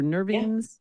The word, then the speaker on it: nervines: How do you pronounce that? Nervines or nervines? – Nervines. Yeah, it's pronounced both ways. I say nervines.